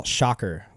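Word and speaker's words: Schacher: Oh, Mel Shocker was his name. Mel Shacher is how it's spelled, but it's Shocker.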